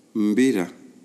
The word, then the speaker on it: mbira: Mbira is spelled M-B-I-R-A, mbira. And we take off the M, B-I-R-A, it becomes that's the name of the ceremony.